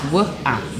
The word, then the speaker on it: Wawa: It's Wuh-ah. Wuh-ah.